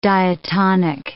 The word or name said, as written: diatonic